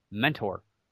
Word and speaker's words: Mentor: It's normally when you see the word mentor, M-E-N-T-O-R, it's pronounced mentor. But us local folks, especially here in Ohio, we pronounce everything kind of weird. Mentor. It's like M-E-N-N-E-R is kind of how we pronounce it.